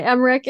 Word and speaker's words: Emmerich: So all right, Maria Egerich, did I say it right? – That's all right, Emmerich.